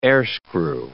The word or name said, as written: airscrew